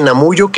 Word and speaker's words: Namuju: YouTube o el canal de Namuyu que eh, se escribe N-A-M-U-J-U.